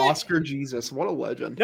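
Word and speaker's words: Jesús: It's Jesus. That's how you pronounce it, douche nugget.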